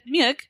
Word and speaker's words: Mueck: It said Muick. – That's right. Yeah. Muick.